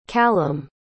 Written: calum

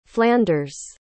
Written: flanders

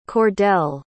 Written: Cordale

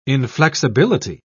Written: inflexibility

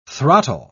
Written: throttle

